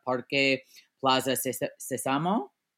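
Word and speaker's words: Parque Plaza Sésamo: Parque Plaza Sesamo. Did I say it right? – Yeah.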